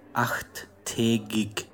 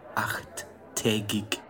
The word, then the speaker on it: achttägig: Achttägig. – Oh, boy. How do you spell that? – A-C-H-T-T umlaut A-G-I-G. Achttägig.